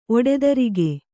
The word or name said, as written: ಒಡತಿರುಗು